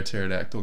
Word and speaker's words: pterodactyl: Can't hear a pterodactyl going to the bathroom? – Because the pee is silent.